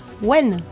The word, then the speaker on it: Maïwenn: Et le film, c'est Jeanne Dubarry, un drame historique, une romance de Maïwenn. – Maïwenn.